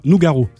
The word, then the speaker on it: Nougaro: Hommage à Claude Noura... Nougaro.